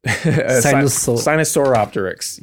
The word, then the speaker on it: Sinosauropteryx: One of the main specimens that they would claim is this Sinoopteryx. How do you pronounce that? – Sinosauropteryx. Yeah. That